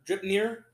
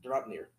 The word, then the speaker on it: Draupnir: And Brock and Atri made the Dripnir for Odin. – Draupnir.